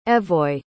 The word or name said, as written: Evoy